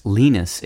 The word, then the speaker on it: lenis: Linguists also borrowed lenis, pronounced lenis in English, L-E-N-I-S, to describe speech sounds that are softened. For instance, the T sound in gutter, G-U-T-T-E-R, is lenis.